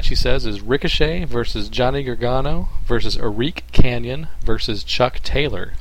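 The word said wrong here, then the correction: Arik Cannon: Eric. It's Eric Cannon. – See, the way it's spelled, I want to say Arik. – Yeah. – Yeah. Okay. Sorry, I know musicians named Arik.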